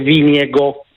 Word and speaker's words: Vingegaard: Per esempio Vingegaard si dice Viniego.